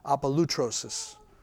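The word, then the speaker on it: Apolutrosis (ἀπολύτρωσις): Apolutrosis. Here, I'll spell it for you. A-P-O-L-Y, which sounds like a U. T-R-O-S-I-S. Apolutrosis.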